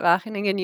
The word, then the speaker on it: Wageningen: Yeah, so I'm in the Netherlands at Wageningen University.